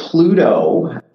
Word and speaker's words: Plooto: What other tools do you find are part of that core so Plooto is a really neat product. It's spelled P L O O T O.